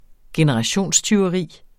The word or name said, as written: generationstyveri